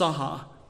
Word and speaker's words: saha: The word worship is found in the Old Testament 165 times the Hebrew word there is pronounced saha, S A H A, and it just means to bow down.